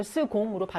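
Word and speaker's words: second: 이걸 스공으로 발음한다 그랬어요.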